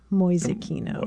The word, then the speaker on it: Mäusekino: M-A-U-S-E-K-I-N-O, Mäusekino.